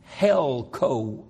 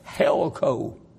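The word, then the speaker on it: helko (ἕλκω): It is the little Greek word helko, H-E-L-K, long O, helko.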